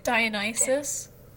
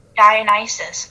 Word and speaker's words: Dionysus: Later writers say Aphrodite and Dinosaurus. How did you say that again? – Dionysus? – Dionysus. Oh my god. I'm so sorry.